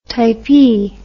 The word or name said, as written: typee